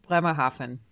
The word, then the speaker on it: Bremerhaven: Off the telephone wires, Wren most recently appeared in No Finer Way, an immersive production in a defunct train station in, am I saying this right? Bremerhaven? Bremerhaven. Bremerhaven, Germany. Nailed it.